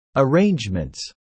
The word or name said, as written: arrangements